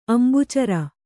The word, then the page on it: ಅಂಬುಚರ: ambucara